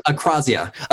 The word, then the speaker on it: akrasia: You use the term akrasia. I think I'm pronouncing that correctly. – Yeah, akrasia. – Akrasia. – So it's pronounced both ways. I think akrasia is the technical one.